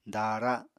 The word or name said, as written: daara